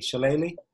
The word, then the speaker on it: shillelagh: An ethnomusicologist, martial artist, sharpener of knives, and maker of fabulous shillelaghs. That's how you pronounce it, or do you pronounce it slightly differently? Shillelagh? – Shillelagh.